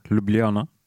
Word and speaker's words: Ljubljana: Men nå skal vi til, tenker vi skal til Ljubljana, hvordan uttaler du det? – Jeg tror du er ganske spot on, Ljubljana. – Ljubljana.